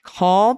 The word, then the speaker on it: Kaul: Attorney General Josh Kaul pronounces his name like Call.